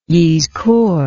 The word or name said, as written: yizkor